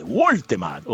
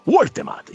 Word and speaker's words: Woltemade: Ma vediamo la Gazzetta dello Sport, segno subito, è sbarcato Woltemade, il gigante della Juve, Woltemade. Oh, mi hanno detto che si pronuncia così, io il tedesco non lo so, abbiate pazienza. Woltemade, il gigante della Juve.